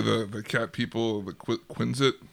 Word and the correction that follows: Kzin: is that how you say it? Quin- Quinits, whatever it is. Like describing those guys. The Kzin. Kzin.